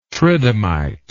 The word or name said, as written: tridymite